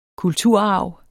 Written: kulturarv

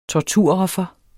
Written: torturoffer

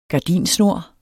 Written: gardinsnor

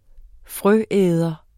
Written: frøæder

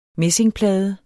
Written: messingplade

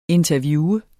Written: interviewe

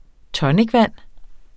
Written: tonicvand

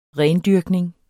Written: rendyrkning

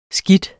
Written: skidt

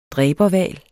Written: dræberhval

